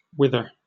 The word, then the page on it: wither: British English